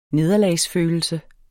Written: nederlagsfølelse

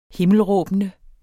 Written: himmelråbende

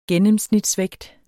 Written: gennemsnitsvægt

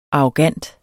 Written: arrogant

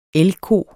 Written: elgko

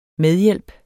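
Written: MEDHJÆLPEN

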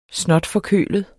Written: snotforkølet

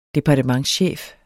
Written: departementschef

